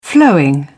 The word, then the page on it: flowing: British English